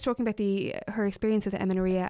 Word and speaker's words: amenorrhea: and she was talking about the effects that amenorrhea, did I pronounce that right? – Yeah.